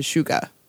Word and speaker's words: Suga: our language with the spelling that we have, it's technically Suga. – Mm-hmm. – But when you spell it in Hangul, the shit and the u make a shu sound. So that's why he when he announces or introduces himself, he says Suga.